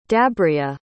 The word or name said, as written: dabria